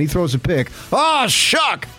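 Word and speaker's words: Shough: It looks like Shuff or... – I know, and I screwed up. – Shuck. – It's Shuck.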